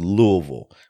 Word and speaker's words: Louisville: So my first cultural artifact is Louisville, which apparently is how you say it, Louisville, like you got a mouthful of marbles.